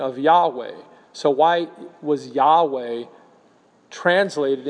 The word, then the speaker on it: Yahweh: The other word for which the Septuagint uses Kyrios is the Hebrew proper name for God, the Tetragrammaton, or YHWH, which is often pronounced Yahweh.